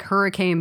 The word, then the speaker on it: Hurricane: Down the road for me, the next town over is spelled like hurricane, but it's pronounced Hurricane.